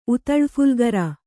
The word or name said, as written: ಉತಳಫುಲ್ಗರ